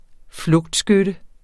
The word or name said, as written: flugtskytte